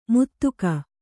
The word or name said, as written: ಮುದುಕ